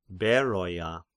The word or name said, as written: Beroia